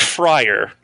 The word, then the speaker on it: Fryer: Think Fryer. Okay. Like, you know, Fryer Tuck, Fryer Lawrence from Romeo and Juliet, Deep Fryer, you know, whatever works.